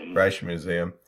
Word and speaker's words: Rijksmuseum: Rijksmuseum.